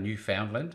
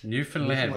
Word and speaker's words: Newfoundland: So how would you pronounce it? – I would have said Newfoundland, but now I've learnt. – Yeah, so Newfoundland. – Newfoundland. Well, there you go.